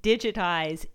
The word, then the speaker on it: Digit-Eyes: And as you can imagine, Digitize isn't spelled like the word digitize. It's actually spelled D-I-G-I-T hyphen E-Y-E-S.